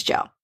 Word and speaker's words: gaol: But G-A-O-L. It's just an old spelling of jail, so it's pronounced the same way as jail.